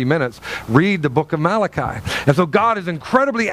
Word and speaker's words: Malachi: And then we get to Malachi, and he's like, God says, listen, if you read the book of Malachi, it's spelled like Malachi, but it's pronounced Malachi.